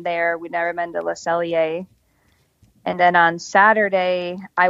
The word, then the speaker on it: Le Cellier: On Thursday, when we go to Epcot, we have lunch at Le Cellier. I probably said that wrong, but no, you did. It's correct.